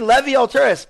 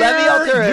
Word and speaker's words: Levi Altrus: So this is going to be Levy Alturas. I said it correctly. – You sure did. New year, Levy Alturas.